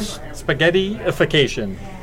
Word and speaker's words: spaghettification: Spaghettification.